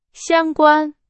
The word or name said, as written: 相关